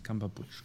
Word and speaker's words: Cumberbatch: Cumberbatch, Cumber. Mein, einer meiner Lieblingsschauspieler. – ja, und einer deiner Lieblingsschauspieler weiß nicht, wie er ausgesprochen wird, ne? Cumberbatch.